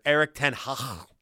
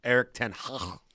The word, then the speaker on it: Erik ten Hag: Erik ten Hag. That's the proper pronunciation, by the way. Erik ten Hag.